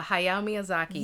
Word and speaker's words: Hayao Miyazaki: Yeah, Hayao Miyazaki.